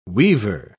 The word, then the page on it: weaver: British English